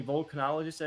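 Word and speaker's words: volcanologist: I think that push kind of fell through in the end, but it follows the careers of these French volcanists who are, I mean, volcanologists, I believe is how you pronounce it.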